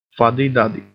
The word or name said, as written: fuddy-duddy